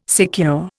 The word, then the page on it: secure: Indian